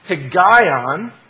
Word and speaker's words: Higayon: This word meditation comes from a Hebrew word that you pronounce Higayon, and you find that actually in English letters in Psalm 9, verse 16.